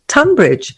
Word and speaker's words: Tonbridge: Tunbridge, which for those of you listening abroad is spelt Tonbridge for some reason.